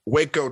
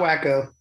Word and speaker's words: Waco: So Texas man, you were born in, I always pronounce this wrong, but Waco, Texas, right? – Yeah, not Waco.